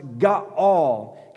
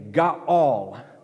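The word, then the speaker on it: ga'al (גאל): And in the Old Testament, in Hebrew, there was a word that's pronounced ga'al. Can I hear all of you say, ga'al?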